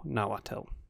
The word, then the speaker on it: Nahuatl: According to Wikipedia, Nahuatl.